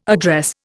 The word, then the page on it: address: Indian